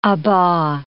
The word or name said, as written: aba